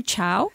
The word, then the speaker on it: Chao: So it's called the CHAO. How do you say that? – Oh, Dr. Chow.